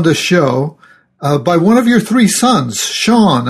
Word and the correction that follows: Cian: Kian. – Kian, is that how you say it? – Okay. Yeah, it's an Irish name. – Yeah, C-I-A-N.